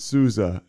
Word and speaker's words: Sousa: I'm Sousa. But you're supposed to pronounce it Souza, and somebody at some point in time took the Z out and threw an S in there, and here we are.